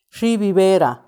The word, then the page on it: xiibi beera: Macuiltianguis